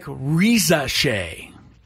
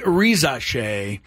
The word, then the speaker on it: Risacher: But it's looking like Rizache. Re, which is the R-I part. Za, S-A. And then C-H-E-R is pronounced She.